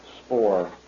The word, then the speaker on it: Spaur: Spohr. – How'd you spell that last name? – S-P-A-U-R, sir.